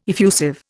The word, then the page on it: effusive: Indian